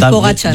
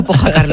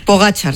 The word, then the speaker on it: Pogačar: Un amigo nos dice también por WhatsApp que pronunciéis por favor bien el nombre de Pogacar. – Pogacar, ah, ya. Es verdad. Yo es Pogačar. – Yo también lo tenía ahí. – Es que yo siempre le digo Pogačar, lo siento. Pogačar, sí, perdón.